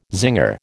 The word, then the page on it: zinger: American English